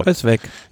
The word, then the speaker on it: Kruijswijk: Mir so oft bei YouTube angeschaut, bis ich wusste, wie der Name genau ausgesprochen wird. – Kruijswijk. – Ja, genau.